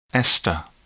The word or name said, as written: ester